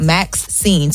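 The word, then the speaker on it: MAXSCENE: So what he's doing, he launched a film distribution arm called Max Scene. So it's spelled M-A-X Scene, like and Scene, mm-hmm. In honor of his mom. Her name is Maxine.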